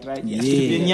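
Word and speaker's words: Estupiñán: Estupinian. – Yeah. – I said it right. Estupinian.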